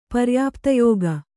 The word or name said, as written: ಪರ್ಯಾಪ್ತಯೋಗ